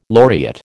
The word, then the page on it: laureate: American English